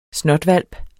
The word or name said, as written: SNOTHVALPENES